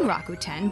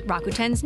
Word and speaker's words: Rakuten: Miki Tani convinced mom-and-pop shops in Japan to join Rakuten by doing push-ups on the sidewalk outside.